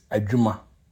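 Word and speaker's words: adwuma: Our next word is Ajuma. Ajuma. The sound focus is the de we. Or DW, de, we. And you pronounce it ajuma.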